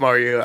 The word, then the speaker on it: Mario: which is the proper pronunciation? I actually have no idea. I've been saying Mario my whole life. – Oh, no, you're right. It is Mario.